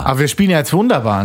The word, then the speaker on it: wunderbar: Geschrieben wird das Wörtchen im amerikanischen so dass es die Amerikaner eben richtig aussprechen. V-U-N-D-A-B-A-R. Wunderbar. Mit V am Anfang.